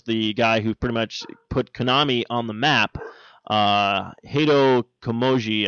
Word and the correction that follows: Kojima: I believe. If I'm pronouncing his name right, but Hideo Kojima. – Thank you, Hideo Kojima.